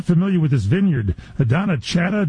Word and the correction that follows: Donnachadh: did I say that right? And you're getting Chardonnay out of there? – Yeah, I think it's pronounced Donna Kay. – Donna Kay. – He said D-O-N-N-A-C-H-A-D-H, Donna Kay. – He said, imagine Sean Connery saying it because it's Celtic in origin.